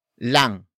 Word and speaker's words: lang: Lamang can be further shortened into lang. Lang. Lang, which is spelled as L-A-N-G.